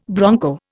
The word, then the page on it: bronco: Indian